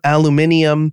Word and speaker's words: aluminium: In England, they pronounce aluminum aluminium.